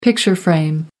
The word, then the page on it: picture frame: American English